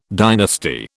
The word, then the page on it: dynasty: British English